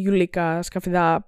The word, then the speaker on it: Γιουλίκα Σκαφιδά: ή η Γιουλίκα Σκαφιδά, έτσι τονίζεται το όνομα του τη, ε, που έπαιζε τη μαμά τη Μαρία.